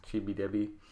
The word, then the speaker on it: Chibi Devi: They pronounce it as Debi in the anime, because the V sound doesn't really exist in Japanese. Chibi Debi.